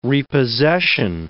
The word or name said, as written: repossession